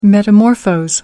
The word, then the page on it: metamorphose: American English